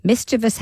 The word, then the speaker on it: mischievous: Other people annoyed by the usage include Richard Dawkins, the evolutionary biologist, who wrote on social media, mischievous has no third eye and is pronounced with a stress on the first syllable, but a mutant I and L on the second syllable is spreading.